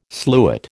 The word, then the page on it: sluit: American English